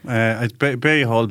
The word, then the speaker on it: Behal: Behill, Behill, depends what part of the country you're in, but it's a French name, which is why it's hard to pronounce.